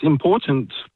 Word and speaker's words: important: There's no D in important, it's important. – It is important. – You've got to pronounce the T's.